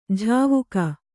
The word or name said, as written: ಝಾವುಕ